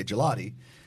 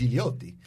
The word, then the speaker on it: Gigliotti: And my cousin's last name and my mom's maiden name, in like we butcher it in English and we say Gelati, but in Italian it's Gigliotti.